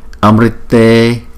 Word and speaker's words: Amrite: Chữ R có dấu nặng này chúng ta đọc là Ri hoặc là Ru, và có một số người đọc là R, chỉ là rồ lên thôi, nhưng mà chúng ta đọc là Ri thì chúng ta đọc Amrite Brahmasware Brahmasware Brahmasware.